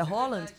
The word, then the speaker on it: Haaland: Você tá me zoando porque não, é verdade, a pronúncia é Holland? É verdade, não, tá bom.